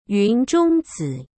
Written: yunzhongzi

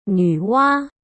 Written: Nuwa

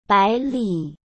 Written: baili

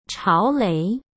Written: chaolei

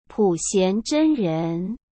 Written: Puxian Zhenren

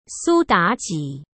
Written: Su Daji

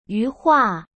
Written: yuhua

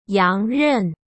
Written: yangren

